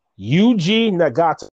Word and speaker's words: Nagata: Yuji Nagata.